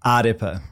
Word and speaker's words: AREPA: How do you say it? – AREPA. AREPA. So you slightly roll the R.